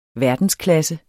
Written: verdensklasse

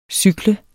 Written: cykle

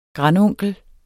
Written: grandonkel

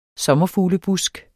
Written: sommerfuglebusk

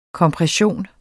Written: kompression

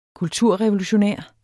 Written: kulturrevolutionær

